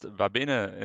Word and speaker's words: Vabene: It's pronounced Vibene in German, but you can say Vibene if you want.